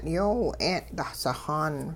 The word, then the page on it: yoowi-'een' dahsixaan: Hupa